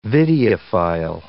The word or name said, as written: videophile